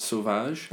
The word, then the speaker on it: Sauvage: This is my guess at their pronunciation. Morisseau and Sauvage. – That's very good.